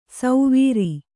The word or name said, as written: ಸೌವೀರಿ